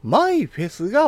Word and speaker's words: MIFES: 約 8 年ぶりに、マイフェスがバージョンアップされるんです。 MIFES と書いて、マイフェスと読むアプリは、聞いたことも見たこともないという人は多いかもしれません。